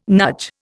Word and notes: NUJ: Indian

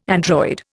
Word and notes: android: Indian

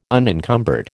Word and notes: unencumbered: American English

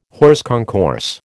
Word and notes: hors concours: American English

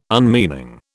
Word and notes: unmeaning: British English

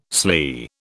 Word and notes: sley: British English

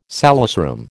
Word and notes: salesroom: American English

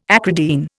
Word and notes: acridine: Indian

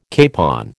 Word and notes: capon: American English

